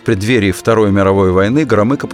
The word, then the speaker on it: Громыко: Министр иностранных дел Андрей Андреевич Громыко – Именно так подчеркнуто внятно произносят фамилию члена Политбюро советские дикторы.